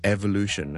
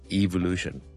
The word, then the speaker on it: evolution: 演化学啦，生物学上嘅演化啦，我哋叫做 evolution 啦，或者 evolution，两个都有人读嘅。